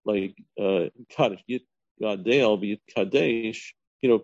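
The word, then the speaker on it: yit gadel (יתגדל): Like sometimes people say yit kadel, but it's yit gadel, yit kadesh.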